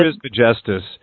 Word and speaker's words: Trismegistus: That's how I would pronounce it. – Trismegistus.